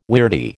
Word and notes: weirdie: American English

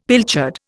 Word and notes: pilchard: Indian

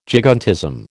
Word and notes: gigantism: American English

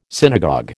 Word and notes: synagogue: American English

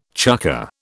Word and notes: chukker: British English

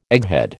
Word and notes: egghead: American English

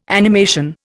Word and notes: animation: Indian

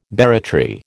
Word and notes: barratry: American English

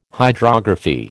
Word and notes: hydrography: American English

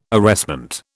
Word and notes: arrestment: British English

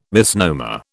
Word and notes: misnomer: British English

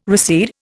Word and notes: recede: Indian

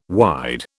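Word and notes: wideawake: British English

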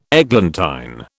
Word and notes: eglantine: British English

